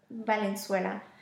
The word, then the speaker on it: Valenzuela: I'm sorry, I've been mispronouncing the last name, the second last name wrong. – We've been working. – Valenzuela.